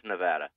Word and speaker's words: Nevada: And I've been saying that wrong. You should correct me. I think Jimmy gets on my case, too. My cousin Jimmy is from there. He says I say Nevada, and it's Nevada, and then I got a couple of letters saying it's Nevada. I'm saying it wrong. How do you say it? – It's Nevada. – It's Nevada. Okay.